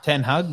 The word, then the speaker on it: Ten Hag: Just roll into the EPO and the big Ronaldo scandal, saying that he doesn't respect his manager, Eric Hartung. How do you say it? – Ten Hag, not Hartung. Not Billy Hartung. Ten Hag.